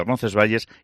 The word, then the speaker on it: Roncesvalles: en la iglesia Santa María de Roncesvalles y Santa Catalina.